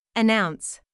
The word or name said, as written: announce